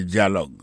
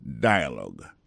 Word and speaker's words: dialogue: Ça veut dire dialogue. Son conversation qui va le faire en deux mondes. Dialogue. L'écrit exactement même genre en français, D-I-A-L-O-G-U-E, mais américain ont prononcé dialogue.